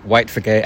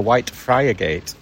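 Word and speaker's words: Whitefriargate: When you're from Hull, it's White Frigate, isn't it? – Frigate, yeah.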